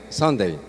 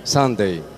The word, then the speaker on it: Sunday: Sunday. – Eso, suena bonito, pero yo no lo sé pronunciar. ¿Cómo es? – Sunday.